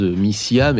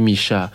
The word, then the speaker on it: Misia: Misha, juste à l'instant, ça s'écrit M-I-S-I-A, donc ça se prononce à la base Missia, mais bon, je crois qu'on dit Misha.